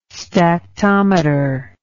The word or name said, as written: stactometer